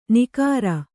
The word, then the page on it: ನಿಕಾರ: nikāra